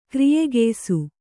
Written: ಕ್ರಿಯೆಗೆಯ್ಸು

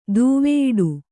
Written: ದೂವೆಯಿಡು

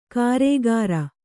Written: ಗಾರೆಕಾರ

